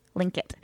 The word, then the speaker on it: Tlingit: Tlingit is actually spelt with a T, like T-G, Tlingit. – Oh, what? So cool. – It's like Tlingit.